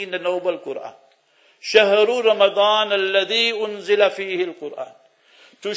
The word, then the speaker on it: Ramadan: They may pronounce it wrong. Ramadan, with a dot.